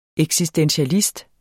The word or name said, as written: eksistentialist